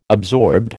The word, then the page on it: absorbed: American English